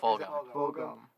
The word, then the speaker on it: Fulgham: Travis Fulgham. If I even said that right. – Fulgham.